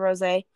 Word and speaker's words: rosé: The apothic rosé, but some pronounce it rose because they don't see the accented E. So some pronounce it rose, but it's actually rosé.